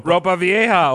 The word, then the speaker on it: Ropa Vieja: Hopa's Vieja? What how do you say it? – Ropa Vieja, old clothes.